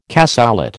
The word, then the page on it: cassoulet: American English